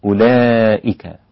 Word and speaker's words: أولئك: الذي يفرق بينهما هي هذه الواو رسمت بعد الهمزة إشارة إلى أن هذه الهمزة مضمومة ولكن ليست لأننا ننطقها بعد الهمزة. حتى ستجد حركة هذه الواو عليها السكون المستدير هذا الذي يشبه الزيرو بالإنجليزية أو ليس الزيرو لكن عليها السكون المستدير الذي معناه أنها لا ننطق هذا الحرف لا ننطق هذا الحرف فيصير النطق به أولئك هكذا.